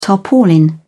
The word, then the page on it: tarpaulin: British English